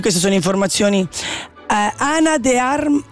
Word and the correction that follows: Ana de Armas: ah, scusatemi, lo pronuncio sicuro sbagliato. Ana de Armas, de Armas è fuori scala, scrive Alex.